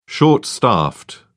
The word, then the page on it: short-staffed: British English